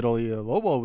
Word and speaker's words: Oyelowo: See, I think I was pronouncing O, yay, like Y-E, and then Lobo, but it's Y-E-L. Oyelowo.